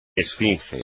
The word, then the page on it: esfinge: espagnol